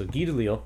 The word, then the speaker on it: Guy DeLisle: So he, you know, French pronunciation is a little different than how we would expected. So Guy DeLisle, and this one is published by Drawn and Quarterly, who is also a French Canadian publisher.